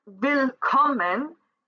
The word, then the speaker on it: Willkommen: So, willkommen, the syllable that is accentuated is that middle one. So, willkommen.